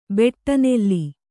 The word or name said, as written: ಬೆಟ್ಟನೆಲ್ಲಿ